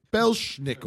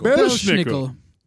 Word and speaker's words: Belsnickel: Belsnickel. – Belsnickel.